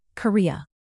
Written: korea south

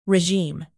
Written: regime

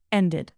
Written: ended

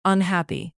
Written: unhappy

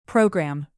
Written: program

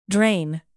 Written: drain

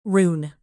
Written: rune

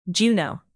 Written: juneau

